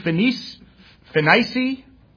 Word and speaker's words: Phoenicia: So they're in Jerusalem and being brought on their way by the church, they passed through Phoenicia, that's how you pronounce it, and Samaria, declaring the conversion of the Gentiles and they caused great joy unto all the brethren.